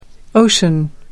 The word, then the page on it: ocean: American English